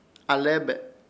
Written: ALEBE

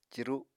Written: ciru

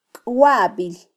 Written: kwabil

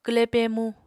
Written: kwle pemu

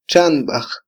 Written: txanbaj

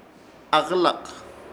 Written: AKƗLAK

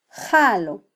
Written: jalo

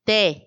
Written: te

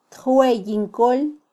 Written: tjweyin kol

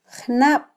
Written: jnab

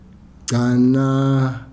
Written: gcana

